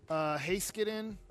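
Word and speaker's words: Heiskanen: I need to, but I want to get Haskinen.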